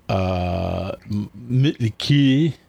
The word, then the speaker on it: Mötley Crüe: Nikki Sixx, who is the bass player of Mötley Crüe. – I'm very proud of you for getting the proper pronunciation. Yep. The umlauts do change how it sounds.